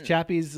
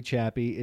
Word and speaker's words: Chappie: Thing to happen from the movie Chappie is the fact that it rhymes with happy, and Happy Hour exists.